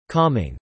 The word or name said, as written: calming